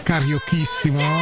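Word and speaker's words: carioquíssimo: scritto col, con la Q e la U e la I, che però si pronuncia cariochissimo, perché gli abitanti di Rio de Janeiro sono i carioca.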